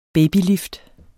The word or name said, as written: babylift